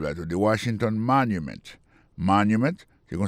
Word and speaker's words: Washington Monument: Si on a mis accent sur accent encore, on va voir que mot Washington, non, accent tombé sur le premier syllabe là.